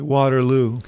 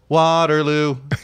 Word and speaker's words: Waterloo: But you said it properly, Waterloo. I had this argument with Barack Obama. I said, remember, it's Waterloo, not Waterloo.